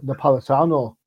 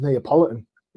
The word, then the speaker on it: Napolitano: It took me 3 months to say Tom Napolitano correctly. You know, it always comes out as Neapolitan, you know, like the ice cream flavor.